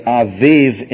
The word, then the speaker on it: Abib: A-B-I-B, usually pronounced Abib in English, but Aviv in Hebrew.